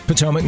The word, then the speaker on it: Potomac: Potomac, Maryland.